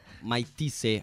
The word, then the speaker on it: Mike Tyson: Porque hoy pelea Mike Tyson, así se pronuncia.